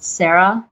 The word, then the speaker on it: Sarah: My name is spelled Sarah with an H, Chapman.